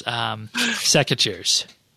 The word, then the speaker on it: secateurs: Scrimmeters. – Not scimitars. Secateurs.